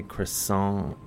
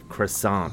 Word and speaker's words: croissant: I don't like to say croissant, and take the T off, or. – Only pronounce the T if there's an E at the end of it.